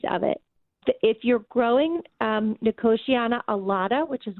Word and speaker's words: Nicotiana: I planted, I believe it's pronounced Nicotania. And it has these beautiful white trumpet flowers on it. And I'm curious how big this plant gets because it's just becoming massive. – Yeah. So in my neck of the woods, we pronounce it Nicotiana.